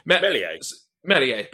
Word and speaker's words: Meslier: I think one of them is called Ilan Meslier. I can't think I've pronounced Melier. That right, probably. – Melier, beg your pardon.